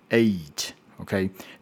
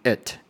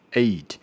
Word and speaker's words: eight: 呃，它的 a 要有 e 的声音在在在中间，eight eight，OK，那很多人就会念太快，就变成 at at，这是错误的。哦，正确的发音是 eight eight。